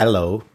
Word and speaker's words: Allo: But with the Allo, I believe that's how you pronounce it, assistant. – Allo. – Allo. – Yeah, to just say hello like Adele would say hello, and that's pretty much a thing.